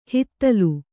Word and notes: ಹುದಲು: Kannada

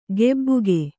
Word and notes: ಗೆಬ್ಬುಗೆ: gebbuge; Kannada

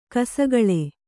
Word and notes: ಕಿಸುಗುಳಿ: kisuguḷi

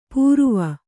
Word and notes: ಪೂರುವ: pūruva

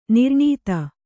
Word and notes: ನಿರ್ನೀತ: nirnīta

